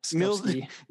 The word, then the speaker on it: Skupski: Is it Skupski? – Pretty sure, yeah. – Skupski.